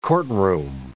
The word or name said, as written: courtroom